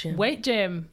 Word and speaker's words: W8 Gym: So talk to me about W8 Gym. Is that how you say it? – Weight Gym. Yes.